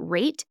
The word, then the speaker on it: R8: It is called Rate, and it's actually R8 is the way the app is written. So it's R8, but you pronounce it Rate.